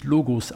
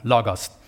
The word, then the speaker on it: Logos: Und tut mir leid, nein, es wird nicht Logos ausgesprochen, das wäre eine andere Schreibweise in der griechischen Sprache. Also Logos ist es.